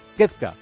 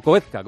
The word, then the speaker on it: Koepka: Rahm está a cuatro golpes, de Koepka, o Koepka, como quieran pronunciarlo.